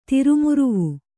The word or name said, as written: ತಿರುಮುರುವು